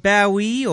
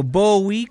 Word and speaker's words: Bowie: The Stars Are Out Tonight de David Bowie o Bowie, como usted lo quiera pronunciar.